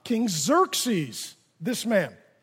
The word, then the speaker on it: Xerxes: Okay, you got to say it like that. Xerxes.